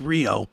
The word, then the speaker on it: Rio: R-I-O. It's spelled like Rio, but it's Rio.